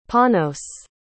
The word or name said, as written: panos